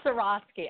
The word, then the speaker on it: Swarovski: Swarovski.